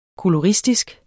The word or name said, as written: koloristisk